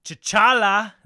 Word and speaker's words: T'Challa: Obviously, our favorite man in a black suit, T'Challa.